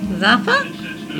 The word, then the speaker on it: Zappa: How do you pronounce an A? – A. – A short A. – Ah. – All right, now how do you say that? – Oh. – It's an Italian name. – Zappa? – Yeah, that makes more sense.